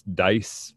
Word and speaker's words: Dyess: I've heard it pronounced a couple ways. Dyess, Dice, whatever. D-Y-E-S-S.